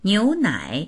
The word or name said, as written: niu2nai3